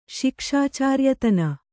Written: ಶಿಕ್ಷಾಚಾರ್ಯತನ

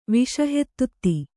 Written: ವಿಷಹೆತ್ತುತ್ತಿ

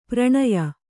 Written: ಪರಿಣಯ

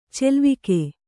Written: ಚೆಲ್ವಿಕೆ

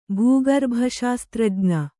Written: ಭೂಗರ್ಭಶಾಸ್ತ್ರಜ್ಞ

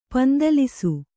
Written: ಪಂದಲಿಸು

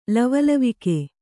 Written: ಲವಲವಿಕೆ

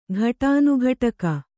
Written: ಘಟಾನುಘಟಕ

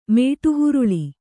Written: ಮಡೆಹೊರಳು

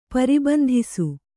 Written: ಪರಿಬಂಧಿಸು

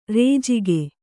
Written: ರಾಜಿಕೆ

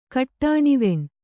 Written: ಕಟ್ಟಾಣಿವೆಣ್

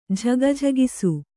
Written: ಝಗಿಝಗಿಸು

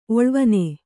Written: ಒಳ್ವನೆ

